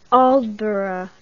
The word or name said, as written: aldeburgh